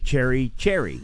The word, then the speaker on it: chary: Cherry. – Yes. – Yeah. – I don't think it was about this word, though. – No. – Cherry, this spelled C-H-A-R-Y. – Oh. – Little different. No E.